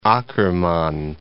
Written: Akkerman

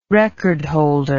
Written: recordholder

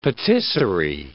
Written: patisserie

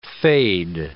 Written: fade

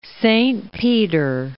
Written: Rome, Duchy of

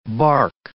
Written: bark

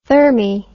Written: thermy

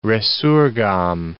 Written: resurgam

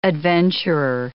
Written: adventurer

